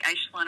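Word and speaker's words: Aisling: So it is a real name, and it's Irish, and it's pronounced more like an SH sound, like Aislinn or Aisleen.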